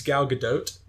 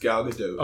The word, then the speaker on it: Gal Gadot: It's Gal Gadot. It's Gal Gadot.